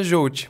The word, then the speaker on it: Jouti: Na verdade, não tem. Eu gosto mais quando fala Jolt. Mas Jolt. falar Jolt também tá certo.